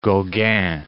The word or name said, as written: Gauguin